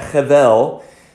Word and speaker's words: hevel: That's the Hebrew rendering of it, and you would pronounce that hevel.